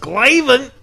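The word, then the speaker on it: Glavine: I believe it's pronounced Glavin.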